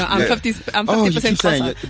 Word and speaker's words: Xhosa: You know, the Zulus down here, the Xhosa. – Yeah, you said it.